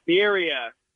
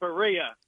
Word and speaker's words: Birria: And I just want to say, it's Birria, not Berea. – Ah, Birria, not Berea. – All right, I was mispronouncing it the whole time. So Birria, like beer.